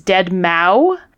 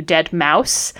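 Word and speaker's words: deadmau5: Marciano rang in and said, who is Dead Mau? And Sanjay Gupta accepted his answer, but corrected the pronunciation to Dead Mouse.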